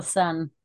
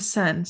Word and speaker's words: Seine: I think it's the Seine. – The Seine.